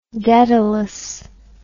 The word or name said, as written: dedalus